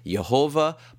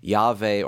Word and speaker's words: Yahweh: The God of the Old Testament was usually referred to with the Hebrew word that transliterates into the letters YHWH, sometimes pronounced Jehovah, but more commonly Yahweh or Yahweh.